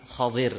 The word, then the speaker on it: Khadir: Nabi Musa alaihissalam dengan Khadir. Ya, bacanya kalau bahasanya Khadir gitu.